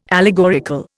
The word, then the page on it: allegorical: Indian